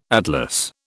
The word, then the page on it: atlas: British English